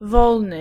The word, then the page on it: wolny: [ˈvɔlnɨ]